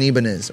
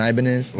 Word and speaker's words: Ibanez: And then, but the first, that guitar was an Ibanez, or an Ibanez. – I always pronounce it Ibanez.